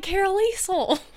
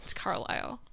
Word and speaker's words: Carlisle: spelled like Carolisle. It's Carlisle.